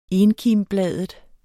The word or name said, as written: enkimbladet